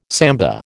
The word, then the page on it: samba: American English